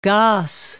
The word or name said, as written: Gosse